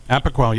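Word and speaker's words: Apoquel: So they put her on, and I'm about pronouncing it correctly, I'm sure, Apoquil. – Apoquil, yeah, you got it.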